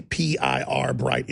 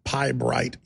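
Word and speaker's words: Pirbright: And sure enough, it's come out that they are the main funders of this one, for the PIR Bright Institute. PIBRIGHT Institute is how it's pronounced.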